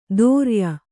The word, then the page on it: ದೋರ್ಯ: Kannada